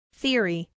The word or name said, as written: theory